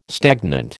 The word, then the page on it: stagnant: American English